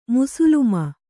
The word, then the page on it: ಮುಸ್ಲಮ: muslama